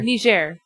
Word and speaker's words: Niger: Niger.